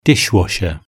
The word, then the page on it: dish washer: British English